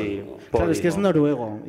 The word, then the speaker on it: Podimo: Pues se llama, ¿dice Podimo o Podimo? – Se dice Podimo.